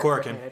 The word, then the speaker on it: Korakuen: Moving on though, New Japan has canceled a number of the Korakuen. I can never know if I'm saying that Korakuen. You're saying it right.